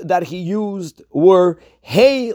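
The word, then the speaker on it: Hey (הא): So firstly, the Rebbe writes that Hey should be pronounced with a Tzedei and not with a Kamatz, not Ho, Lachma, Anyo, but Hey, Lachma, Anyo, meaning this is the bread of affliction.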